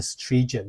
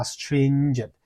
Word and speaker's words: stringent: 是可以，那这边要注意一下，借着什么呢？A raft of，a raft of，大量的，大量的，raft 这个字叫做木筏，但是片语说 a raft of 就是大量的， stringent，stringent，请注意我的发音哦，string 有个鼻音，对不对？有 n，每一个字母在单字里面的发音都是有意义的，所以你不可以发成是 stringent，你要发 stringent，严格的。